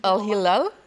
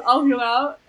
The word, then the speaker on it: Al-Hilal: Al-Hilal? – Ja, sehr gut. – Irgendwie so. – Genau, so Al-Hilal.